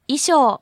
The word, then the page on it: 衣装: いしょう